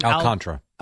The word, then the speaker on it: Alcantara: So how do you say his last name? – Alcantara. – Alcantara? – Yeah.